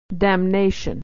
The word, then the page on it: damnation: dam-na-tion